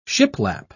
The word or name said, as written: shiplap